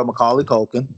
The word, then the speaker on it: Macaulay Culkin: You got your boy Macaulay Culkin.